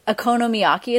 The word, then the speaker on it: okonomiyaki: And okonomiyaki is how you say that pancake? – Uh-huh.